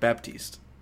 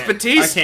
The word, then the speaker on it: Baptiste: He said it wrong. I pronounced it right. So I we both said it. – I said Batiste. You said Baptiste.